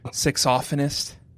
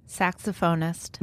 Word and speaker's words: saxophonist: Saxophonist. – Okay, fine. – Did you say saxophonist? – Yeah, of course. That's how it's pronounced.